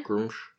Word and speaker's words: Gruumsh: Do you pronounce these separately? – There's Grumsh, two. Grumsh one eye. – Grumsh.